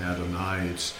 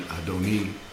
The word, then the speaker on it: Adoni: Adonai is how most English people speak and sing, but the letter in the Hebrew is actually pronounced like a long E instead of an I, not Adonai. It's pronounced because of the punctuation Adoni or Adoni, like that.